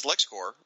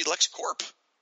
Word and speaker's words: LexCorp: Every everyone says LexCorp, including myself, but really it should be LexCorp. You know, it's we say core like Marine Corps, but this is corporation.